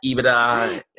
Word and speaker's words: EBITDA: It's EBITDA.